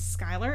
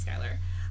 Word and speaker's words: Schuyler: Anyway, eventually he did settle down with a woman, Elizabeth, and the pronunciation is Schuyler. It's not spelled that way. I will put the spelling in the show notes. But everything I'm seeing is pronounced Schuyler.